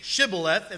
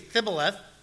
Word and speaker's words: Shibboleth: If they're from Ephraim, if they can't say Shibboleth, if they say Thibboleth, kill them.